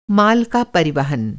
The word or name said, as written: परिवहन